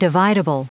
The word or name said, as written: dividable